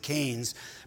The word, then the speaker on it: Keynes: He says, in his 1930 essay, going way back, called Economic Possibilities for Our Grandchildren, the economist John Maynard Keynes, I believe is how you pronounce it, Keynes, predicted a 15-hour work week in the 21st century, creating the equivalent of a five-day weekend.